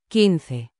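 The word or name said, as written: quince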